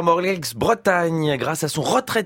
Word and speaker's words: Morlaix: Mais oui, les policiers de Morlaix ont reçu une carte postale les remerciant d'une... Morlaix, me dit-on dans l'oreille. – Attention, évidemment, on ne prononce pas de X, c'est autant pour moi.